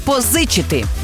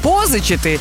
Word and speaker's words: позичити: Наголос завжди на другому складі – позичити. Бо якщо скажете позичити, то вийде не граматика, а новий стиль танців.